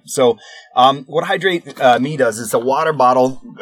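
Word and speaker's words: Hidrate Me: Another one that was cool on there that I saw is called Hydrate Me, and it's spelled like this, HIDRATE, Hydrate Me.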